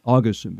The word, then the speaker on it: Augustin: Augustin, because that's it's the accent on the right syllable. Got to get right.